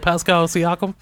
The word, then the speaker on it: Pascal Siakam: You know, did I say it right? Pascal Siakam? – Yeah, that's right.